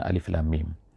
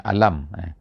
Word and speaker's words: Alif Lam Mim: Alif lam mim, bukannya alam. Ada di tempat-tempat yang lain dalam Al-Quran ini, kadang-kadang alam tara kaifa fa'ala, alam tu maknanya lain. Alam tu ejaannya sama, alif lam mim, tapi bacaannya alam.